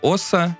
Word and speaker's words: Оса: Это совершенно музыкоцентричная запись, где, Салу, где Салуки и его компаньон Оса, мне кажется, так надо ставить ударение в этом, э, в никнейме этого продюсера, делают, что хотят.